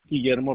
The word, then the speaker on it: Guillermo: Guillermo Rodriguez.